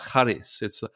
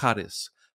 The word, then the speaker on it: charis: And I can't say it properly because I'm not a Greek person, but it's something like charis. It's not a hard k. It's a soft charis. Charis. And gift. And that's, sorry, that's grace. Charis.